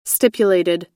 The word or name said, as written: stipulated